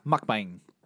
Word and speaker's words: mukbang: Yeah, mukbang.